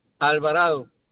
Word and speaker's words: Alvarado: Douglas, se escribe Douglas Alvarado. A-L-V-A-R-A-D-O.